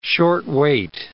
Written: short-weight

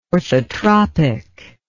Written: orthotropic

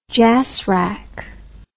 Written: jasrac

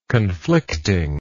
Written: conflicting

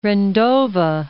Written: Rendova

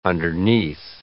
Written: underneath

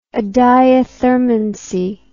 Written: adiathermancy

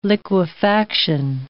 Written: liquefaction

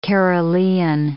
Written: Carolean

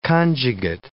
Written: conjugate

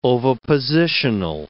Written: ovipositional

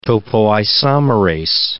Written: topoisomerase

